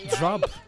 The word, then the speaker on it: Job: J-O-B. Job.